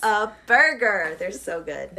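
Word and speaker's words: Whataburger: Well, you pronounce it Whata Burger, but, like. – I said it wrong. I failed. – No, you said it right. – You said it right, but, it took me forever to understand that it's supposed to be what a burger.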